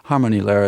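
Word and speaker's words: Harmonielehre: Am I pronouncing that right? – Harmonie Lehre. It's a German word, which I roughly translate, meaning the Book of Harmony.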